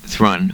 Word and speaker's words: Thrun: My Sebastian Thrun, and I don't know if that's the correct pronunciation. – I think it's Thrun. – Thrun, who developed the first Google car.